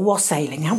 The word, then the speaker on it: wassailing: So could we just very, very briefly just touch on wassailing or wassailing? What's the pronunciation? – Well, I mean, I use the word wassailing.